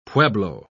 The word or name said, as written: pueblo